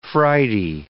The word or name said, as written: Friday